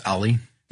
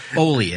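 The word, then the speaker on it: Ole: Ollie. – Yeah, so five, right. – Oli, I think is Oli, how they say it.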